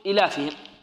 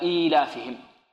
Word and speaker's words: إيلافهم: خافوا أن يقرأها القارئ يقول إلافهم، فهنا جاءوا بيا صغيرة صغيرة الحجم وضعوها في هذه الكلمة كي ينطقها ماذا؟ أو كي ينطقها قارئ القرآن. إذن ننطق هكذا إيلافهم، لإيلاف قريش إيلافهم.